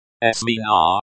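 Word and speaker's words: смена: Pronunciation. Smina. Stress on the first syllable.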